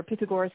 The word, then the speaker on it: Pythagoras: And Pythagoras, or Pythagoras in Greek pronunciation, was actually the father of what we call sacred geometry.